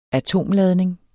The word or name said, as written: atomladning